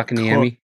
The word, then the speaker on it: Kotkaniemi: Kotkaniemi.